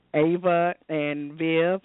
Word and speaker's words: Ava and Viv: I know um, Chastity, you just did a, a campaign with Target for for Ava and Viv. Am I pronouncing it correctly? – That's correct.